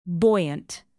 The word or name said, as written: buoyant